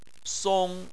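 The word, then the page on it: song1: tone 1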